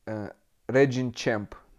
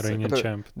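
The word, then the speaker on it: Reigning Champ: И вторая была коллаб с канадским брендом Regin Champ. Называю, вроде так правильно произносится. – Regin Champ.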